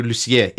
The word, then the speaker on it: Lussier: You know, I got so tired of people mispronouncing my last name in elementary school, I would go, Matthew Lussier, it's French.